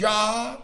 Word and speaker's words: Jah: That J-A-H, Yah or Yah, it's a shortened form of the word Jehovah. And so they're calling God Jehovah. Here it is spelled out J-A-H.